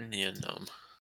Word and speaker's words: Nien Nunb: This is about Nien Nunb.